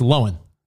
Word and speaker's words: Lohan: Well, just recently, Spot, you've always said it right, but recently, didn't Lindsay Lohan say that everyone that says Lindsay Lohan, it's not Lohan, it's Lohan. Well, like, almost one syllable, like Lohan.